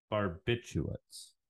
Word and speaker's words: barbiturates: But barbiturates can cause constipation. So it's believed. Barbiturates. – Okay, thanks. – Yes. Am I pronouncing that wrong? – No, I just wasn't sure what I heard in there.